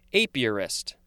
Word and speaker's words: apiarist: Apiarist. I think that's uh, a better way to pronounce it.